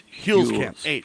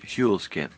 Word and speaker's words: Huelskamp: We got to just tell you really quick that Tea Party rep Tim Muehlkamp loses his Kansas Republican primary to Roger Marshall. – Did you say Muehlkamp? It's Huelskamp. – Huelskamp. Yeah, okay.